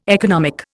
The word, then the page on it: economic: Indian